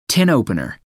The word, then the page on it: tin opener: American English